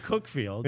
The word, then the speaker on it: Cuckfield: I didn't know They pronounce it Cuckfield, but it's from Cuckfield.